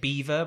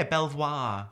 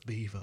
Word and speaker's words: Belvoir: There's a place that like they say it Beaver, but that's not how it's said. – Oh yeah, Belvoir. – Belvoir. Oh yeah. It is literally spelled Belvoir. Okay. As like, and then there's no confusion when you see it. That's how it looks like it's spelled. And then people give you like jip when you go there because like no, it's Beaver.